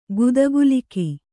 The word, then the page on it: ಗುದಕೀಲಕ: guda kīlaka